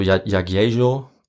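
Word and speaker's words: Jagiełło: Ich spreche das jetzt deutsch aus. – Ja. – Also den Jagiello, den müsste man eigentlich äh, eher so Jagiello aussprechen. – Mhm. Władysław Jagiello. Aber das ist für mich zu schwierig. Also ich sage einfach Jagiello.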